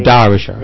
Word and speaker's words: Derbyshire: Derbyshire. Well, you say Derbyshire actually. – Derbyshire. – Very strange. Yeah, Derbyshire. With okay. – You pronounce the as if it was an A.